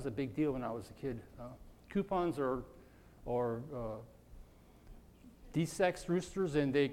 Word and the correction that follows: capon: Capon, I did say it wrong, yeah. Coupon, capon, it's a capon.